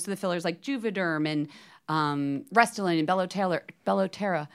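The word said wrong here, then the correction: Belotero: I believe are 6 months to a year, just so people know that if they didn't realize There's one called Bellotera. – Bellotero. I'm saying it wrong, yes.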